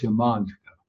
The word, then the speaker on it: Yamantaka: But we talked about two A's together becoming a long A. – Wir haben ja schon drüber gesprochen, dass wenn zwei A's aufeinandertreffen, wird es ein langes A. – So ist Yamantaka. – Also ist es Yamantaka.